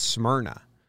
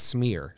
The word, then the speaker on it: Smyrna: I actually think it's Smyrna, is how they say it. Not Smear, Smur. Smyrna, Delaware.